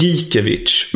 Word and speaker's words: Gikiewicz: Rafael Gikiewicz. – Ja, det är fan bra. Jag tror det är Gikiewicz, men det är väldigt nära. Gikiewicz är bättre.